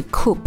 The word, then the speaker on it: CUP: 之前可能有听我聊到哦，古巴有两种钱，一种叫 CUP，一种叫 CUC，我们发音就是 coup 跟 cook，其实这两个真的很相近啊。那所以每次在古巴都要非常明确的去跟对方确认，你跟我报的价是 cook 还是 coup。